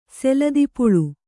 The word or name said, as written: ಸೆಲದಿಪುಳು